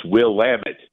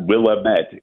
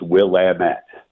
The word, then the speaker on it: Willamette: It's not Willamette, it's Willamette.